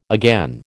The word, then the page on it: again: American English